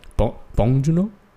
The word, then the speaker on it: Bong Joon-ho: Bong Joon-ho? – Yeah, that's pretty good.